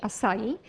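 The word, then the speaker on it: açaí: Pisze się acai, ale czyta się acai.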